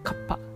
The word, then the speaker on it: cuppa: カッパです CUPPA ですね発音だけ聞くと、えー、まさしく日本のカッパなんですけども